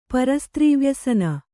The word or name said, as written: ಪರಸ್ತ್ರೀವ್ಯಸನ